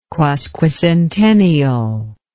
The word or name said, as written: quasquicentennial